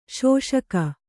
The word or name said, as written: ಶೋಷಕ